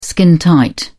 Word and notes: skintight: British English

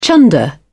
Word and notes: chunder: British English

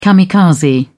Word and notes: kamikaze: British English

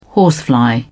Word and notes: horsefly: British English

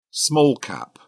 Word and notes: small-cap: British English

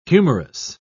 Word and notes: humourous: British English

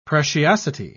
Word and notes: preciosity: British English

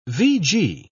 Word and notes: vg: British English

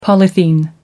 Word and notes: polythene: American English

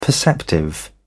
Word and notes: perceptive: British English

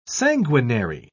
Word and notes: sanguinary: British English

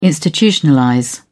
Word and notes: institutionalize: British English

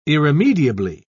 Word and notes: irremediably: British English

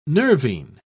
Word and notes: nervine: British English

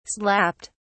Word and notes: slapped: British English

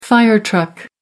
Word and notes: fire truck: American English

